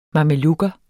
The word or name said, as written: mamelukker